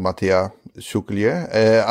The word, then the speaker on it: Matija Šuklje: So welcome in this episode, we're having a guest called Matija Šuklje. I hope I pronounced that correctly. – It was close enough. You could just drop the J. So Matija Šuklje is how you probably pronounce it.